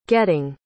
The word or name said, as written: getting